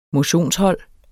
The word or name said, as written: motionshold